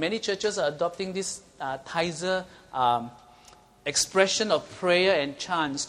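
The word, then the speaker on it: Taizé: Some of the churches actually go into this thing called, have you heard of the, how do you pronounce T-A-I-Z-E? Tizer, Tizer. Is it Tizer? Is it called Tizer? Tizer. Is it Tizer, anyone? How do you pronounce that? Is it Tizer, okay.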